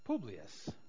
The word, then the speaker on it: Publius: Publius.